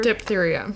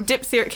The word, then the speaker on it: diphtheria: Diphtheria. – Diphtheria.